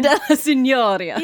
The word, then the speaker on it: Piazza della Signoria: It's a big statue called Big Clay in the Piazza della Signoria. I don't know if that's how you say it. Does that sound Italian to you? – It does. I like your pronunciation. – Piazza della Signoria.